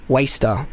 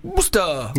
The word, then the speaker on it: Worcester: So the producers very, very kindly said very clearly, this is pronounced Waster. Because I definitely would have gone Worcester.